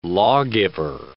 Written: lawgiver

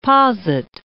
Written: posit

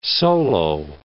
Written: solo

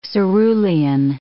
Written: cerulean

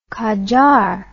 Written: qajar

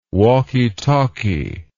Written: walky talky

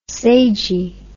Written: sagy

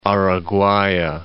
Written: Araguaya

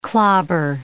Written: clobber

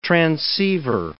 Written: transceiver

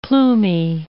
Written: plumy

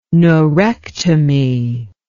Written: neurectomy

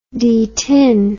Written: detin

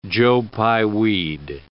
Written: joe-pye weed

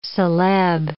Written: celeb

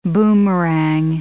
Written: boomerang

